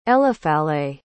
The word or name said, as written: eliphalet